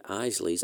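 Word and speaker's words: Eisele: It should be pronounced like Isley.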